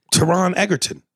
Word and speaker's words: Taron Egerton: Taron Egerton. T-A-R-O-N, then it's E-G-E-R-T-O-N. I can't say shit. – Taron Egerton.